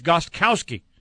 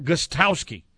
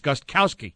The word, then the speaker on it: Gostkowski: People mostly think the K is silent. It's not. It's Gostkowski. You're supposed to pronounce everything. Gostkowski. Sometimes that's a little tough to do, but you pronounce everything, including all of the consonants in there.